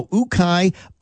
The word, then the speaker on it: ukay: Now, Ukai, it's a Filipino term. It means to dig. It's spelled UKAY. It's pronounced Ukai.